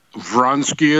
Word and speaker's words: Vronsky: Vronsky it is.